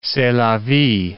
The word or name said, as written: c'est la vie